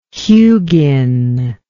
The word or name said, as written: Hugin